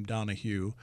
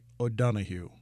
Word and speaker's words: Donohue: it's because I pronounce my name Donohue, and she said that the correct Irish pronunciation would be O'Donohue.